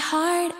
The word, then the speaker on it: heart: Aquí, un pequeño tip de pronunciación es que la palabra heart la pronuncias con una A en vez de una E. Heart. Además, también tenemos una palabra muy útil, attempt, que significa intentar o intento.